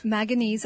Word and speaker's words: manganese: Think of the word mango. Manganese.